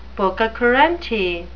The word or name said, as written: pococurante